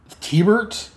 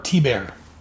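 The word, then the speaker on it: Thibert: And the cover on this was, I've always pronounced it Art Tebert, but listening to the Fire and Water podcast, they say it's pronounced T-Bert, so, or T-Bear. So its artist is Art T-Bear.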